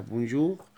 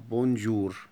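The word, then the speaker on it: bonjour: ممكن صح نقول عليها بونجور، وممكن برضو نقول عليها بونجور، لأن حرف الار، حرف الار اللي هو الار يعني اسمه ار بالفرنساوي، حرف الار ده بينطق ساعات ار، وساعات ممكن نقول عليه اغ عادي، حسب. في ناس بتنطقوا اغ وفي ناس بتنطقوا ار عادي.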